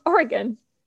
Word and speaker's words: Oregon: So, on that, every fall, a hillside along Oregano. How do you say that? – Oregon. – Oregon. – Or Oregano. You should know the state.